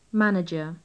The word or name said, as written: manager